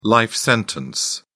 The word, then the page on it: life sentence: British English